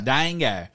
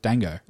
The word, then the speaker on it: dango: No, well, we're talking about dango. – Yeah, dango. Which I actually looked up how you pronounce it, and that is correct. Dango.